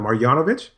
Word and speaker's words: Marjanovic: Is that Marjanovic? – Sure.